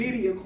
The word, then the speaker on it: Birria: You know, gotta say it right. It's the Birria Queen.